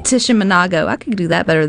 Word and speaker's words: Tishomingo: Uh, Tisho, Tishkomo, how you say it? Tishomingo, Tishomingo Park, State Park. Tishomingo.